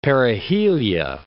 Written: perihelia

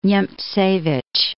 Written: Niemcewicz

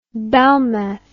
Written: belmez

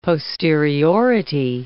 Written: posteriority

